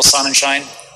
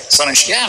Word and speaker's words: Sonnenschein: You got Genesis presentation, CEO, Grayscale, Michael Sonnenschein. Oh, I didn't say that right. Sonnenschein.